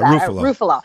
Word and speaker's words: Ruffalo: How do we say that? – Ruffalo. Mark Ruffalo.